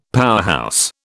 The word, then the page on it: powerhouse: British English